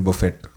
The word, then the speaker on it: Buffett: Warren Buffet or yeah. Warren Buffett? – No, Buffett, yeah. – Warren Buffett. – Yeah, I used to say Buffet.